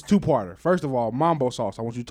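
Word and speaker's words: Mumbo: It's Mambo with a U, boo.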